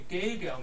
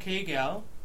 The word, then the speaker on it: Gilgal: Is it spelled like this? Gilgal in English? Gilgal? – G-I-G-A-L, right? – G-I-L. – Gilgal.